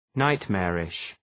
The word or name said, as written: nightmarish